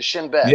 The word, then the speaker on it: Shin Bet: Do you know that the Shin Bet the Shin Bet, okay? Did I say that right? The Shin Bet.